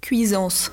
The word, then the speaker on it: Cuisance: Is it Mikael Cuisance? – Cuisance.